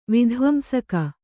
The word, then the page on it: ವಿಧ್ವಂಸಕ: vidhvamsaka